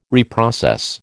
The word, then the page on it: reprocess: American English